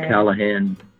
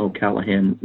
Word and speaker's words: O'Callaghan: O'Callaghan. I think it has a at Tom O'Callaghan with a underscore as the at the end of O'Callaghan, and the last name is spelled O-C-A-L-L-A-G-H-A-N.